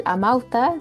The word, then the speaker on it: Amauta: Los invitamos a leer el Amauta, si ahora lo dije bien. – Muy bien.